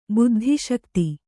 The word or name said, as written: ಬುದ್ಧಿಶಕ್ತಿ